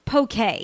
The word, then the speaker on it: poke: It's actually a culinary conundrum that few on the continental U.S. can actually pronounce. It's poke, by the way, and it's the next big thing in fast food.